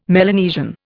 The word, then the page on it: Melanesian: Indian